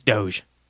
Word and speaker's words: Doge: So Eichmeyer points out that maybe it's Doggy Coin. – No, D-O-G-E. Doggy Coin. – It's Doge. – Well, but you pronounce Eichmeyer's name Eichmeyer, so... – It's Doge. – It might be Doggy Coin. – No, it's Doge. It's definitely... – You might be onto something. – It's totes Doge.